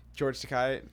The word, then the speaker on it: George Takei: George Takei, is that how you say his name? – Yeah. He has like the best like ambiguous like non-West, like I don't know, non-white accent.